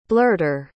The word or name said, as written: blurter